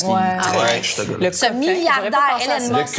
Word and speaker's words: Elon Musk: Oh, le coquin Elon Musk. Ça, je l'ai-tu bien prononcé? – Tu l'as bien dit.